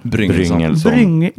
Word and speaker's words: Bryngelsson: Bryngelsson. – Bryngelsson. Jag har nog sagt alltid Bryngle. Ja. Men det är fel.